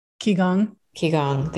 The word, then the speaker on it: Qigong: Qigong.